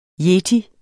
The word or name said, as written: yeti